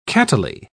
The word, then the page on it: cattily: British English